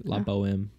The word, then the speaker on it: La Boheme: I mean, it's not even, I don't even think you're supposed to pronounce the H. It's like La Boheme.